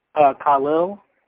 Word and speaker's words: Khalil: Khalil. – How do you spell that? – Oh, K-H-A-L-I-L.